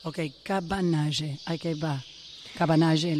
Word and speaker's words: Cabanagem: Cabanagem, em decorrência. – Cabanagem, espera, decilo mais despacio. – Cabanagem.